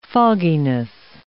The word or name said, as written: fogginess